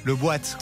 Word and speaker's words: Le Boat: Et alors Le Boat, ça s'écrit Le... puis boat, B-O-A-T, le boîte.